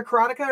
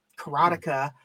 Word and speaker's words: Karateka: Karateka. Is it a Karateka or Karateka? I've always assumed it was Karateka.